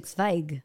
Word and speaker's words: Zweig: Number four, The Light Work by Jessica Zweig. By the way, I'm pronouncing it correctly. – You are, of course. Well, you know my... – It's actually Zweig.